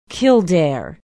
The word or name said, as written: kildare